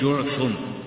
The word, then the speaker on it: جعشم: قال: فقام سراقة بن مالك ابن جعشم، هكذا تلفظ جعشم، فقال: يا رسول الله، ألعامنا هذا أم لأبد؟